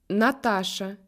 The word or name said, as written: Natacha